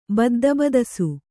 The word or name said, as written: ಬದ್ದಬದಸು